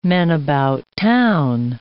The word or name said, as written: men-about-town